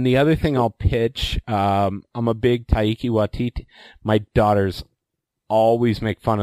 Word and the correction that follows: Taika Waititi: of the way I say, I think it's supposed to be Taika Waititi.